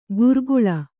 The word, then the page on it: ಗುರ್ಗುಳ: gurguḷa